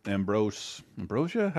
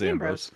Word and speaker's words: Ambrose: I mean, the cast seems to be Lauren Ambrosia. How do you say her name? I think it's Ambrose.